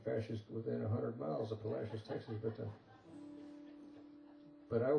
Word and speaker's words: Palacios: Those blue letters indicate that, or suggest, that somebody a long time ago named that town Palacios. But modern-day Texans pronounce it Palacios. which kind of sort of rhymes with fascist if you think about it.